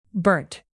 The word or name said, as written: burnt